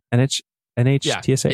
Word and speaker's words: NHTSA: Is that how you say it? NHTSA? Yeah.